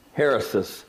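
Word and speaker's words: hairesis: It's spelled H-E-R-E-S-I-E-S in English. In the Greek, it's spelled H-A-I-R, like hair, heresis.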